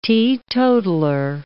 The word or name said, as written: teetotaller